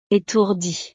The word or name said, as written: etourdie